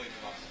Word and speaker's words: Nevada: If you ask someone from Nevada, they'll say Nevada, but it's really Nevada. – So somebody Nevada. – From Nevada says Nevada.